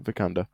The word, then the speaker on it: Vikander: and the most recent Tomb Raider adaptation with Alicia Vikander. Pretty sure I'm pronouncing that right. – Yeah, Vikander.